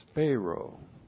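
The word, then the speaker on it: speiro: S-P-E-I-R, long O, spero.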